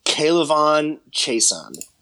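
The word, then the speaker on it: K'Lavon Chaisson: I think that they go out and get I'm going to butcher his name. Kalyvon Chaisson. – You nailed it, man.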